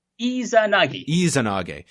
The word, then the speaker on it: Izanagi: Is that how you pronounce it? – Izanagi.